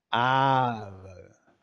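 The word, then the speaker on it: AARO: It's now Arrow, but quite a long A. Arrow.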